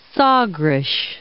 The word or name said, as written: Sagres